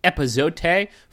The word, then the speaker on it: epazote: Because they got chili blackened cod with epazote. Fuck, I didn't say that right, probably. – Grapefruit. No, I think you nailed it. – Chili blackened cod with epazote, grapefruit, avocado, and rice salad.